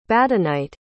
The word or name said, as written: badenite